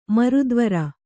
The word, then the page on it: ಮೂರ್ತಿವೆರು: mūrtiveru; Kannada